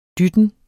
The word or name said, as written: dytten